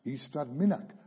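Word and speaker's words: Ystrad Mynach: Eastrad Minnach, pronounced Eastrad Minnach, according to Google.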